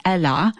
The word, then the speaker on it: Ellur: We just heard God Help Me Now by a British singer-songwriter named Eller. If you're looking for her, it's spelled E-L-L-U-R, but it's pronounced with a British accent, Ella.